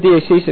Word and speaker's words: deesis: Okay, now that is the word deesis, if anyone's interested. Deesis. D-E-E, long E, second one, S-I-S, deesis.